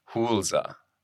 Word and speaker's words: Huelse: So she always says phonetically, it's spelled H-O-O-L-Z-A. Hulza.